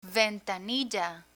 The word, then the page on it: ventanilla: Spanish